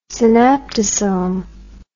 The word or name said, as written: synaptosome